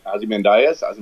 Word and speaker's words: Ozymandias: Um, the Watchmen had a lot of that with uh, how do you pronounce it? Osman Ozymandias, right? Yes.